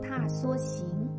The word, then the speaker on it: 踏莎行: 这句词的词牌名称念作《踏梭行》，梭是指梭草，而踏梭是指户外踏青郊游。